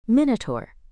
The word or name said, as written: minotaur